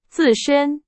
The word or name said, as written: 自身